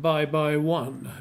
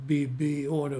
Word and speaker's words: Bibione: Och då var det, jag vet inte om hon bodde i badorten Bibione. Men den uttalade engelsmännen som bye bye one. Bibione, bye bye one. Å andra sidan så uttalade tyskarna som Pippione. För de har svårt med mjuka och hårda B och P.